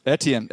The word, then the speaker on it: Etienne: How, how would we say his last name? – Etienne, Etienne, Etienne, yeah.